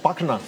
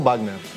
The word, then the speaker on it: Wagner: Y en Alemania es Wagner, no Wagner.